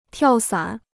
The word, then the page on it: 跳伞: tiào sǎn